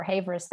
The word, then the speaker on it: Havers: Isabelle Havas. – But if you go for Havers, that's totally fine, because I guess more English-speaking people could find me.